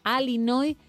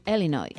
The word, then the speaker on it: Illinois: La I la pronuncian como una E. – Como una E, sí. – Pero no como una A-I, que es lo que decía el doctor. Correcto. Una cosa es Illinois y otra cosa es Illinois.